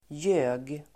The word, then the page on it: ljög: [jö:g]